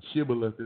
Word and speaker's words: Shibboleth: Shibboleth is the right pronunciation, just in case I got some Bible folks out there.